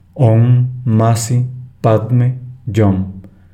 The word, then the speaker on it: Om Mani Padme Hum: Realizar los mantras Om Mani Padme Hum, y se pronuncia Om Masi Padme Yom, alargando el sonido de cada letra y en forma silabiada.